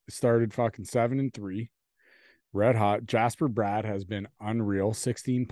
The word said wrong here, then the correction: Jesper: I want to say Sweden, so it's pronounced Jesper, but yeah.